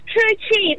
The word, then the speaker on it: Toochi: Yes, no, it's Toochi, that's correct.